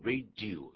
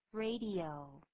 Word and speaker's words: radio: 这个 radio 不要念成 radio, 不是这样念 radio, 应该怎么念呢？ – 三个音节 ,radio, radio.